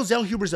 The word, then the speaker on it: Zellhuber: I added a syllable the first time I said it. Daniel Zellhuber's ability to avoid a slugfest,